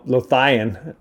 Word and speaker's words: Lothian: So I think it's Lothian, and I call him Lothian. And I think now he even has changed the pronunciation. He thought that sounded cool. It's like Leviathan, Lothian.